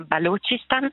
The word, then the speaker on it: Balochistan: Allora, già la pronuncia in realtà ci sono stata, sono confusa tanto quanto voi, nel senso che si chiama Balochistan, è così come lo chiamano la maggior parte delle persone, ma anche lì lo chiamano in modi diversi.